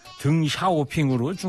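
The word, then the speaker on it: 등샤오핑으로 (鄧小平): 그래서 이제 중국말로 읽을 땐 시진핑이고, 등샤오핑은 등소평인데 등샤오핑으로 중국어로 발음하는 것 같더라고요.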